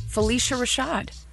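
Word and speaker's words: Phylicia Rashad: Felicia Rashad. – You're saying it right. – Felicia Rashad.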